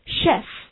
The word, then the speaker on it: chef: 这个 chef 就是这个大厨和主厨的意思， chef，注意这个发音是 chef，发 sh 音而不是 ch，而 sh chef salad。